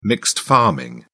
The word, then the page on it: mixed farming: British English